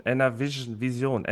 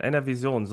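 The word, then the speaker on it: NRWision: Auch wir veröffentlichen auf Fachplattformen, zum Beispiel im Jugendhilfeportal oder eben auch NRW Vision, NRV Vision, NRV Vision, so spricht man das aus, musste jetzt ein bisschen üben.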